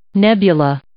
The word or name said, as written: nebula